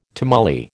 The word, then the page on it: tamale: American English